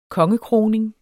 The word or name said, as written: kongekroning